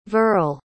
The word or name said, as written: verle